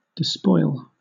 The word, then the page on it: despoil: British English